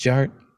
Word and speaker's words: DJarTT: DJ Art, with two T's for a double dose of that tink tink. And the D is silent, so it's just Jart.